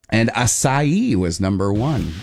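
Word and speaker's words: açaí: Or acai. A-C-A-I. – How do you say it? Acai? – Acai.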